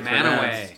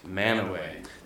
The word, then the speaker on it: Mantua: It's spelled Mantua. – Oh. – But it's Manaway. – Manaway.